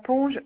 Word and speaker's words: Ponge: Madame Pont Evelyne. – Alors, ça s'écrit P. – ONGE.